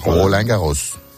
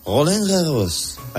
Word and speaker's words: Roland Garros: ¿Cómo se dice Roland Garros, tú que sabes francés? – Roland Garros. – Roland Garros. Vale. – Sí, el acento de la O no es tan así, pero bueno.